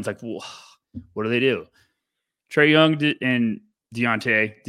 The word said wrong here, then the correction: Dejounte: Dejounte, what Spurs fans always yell at me for my pronunciation.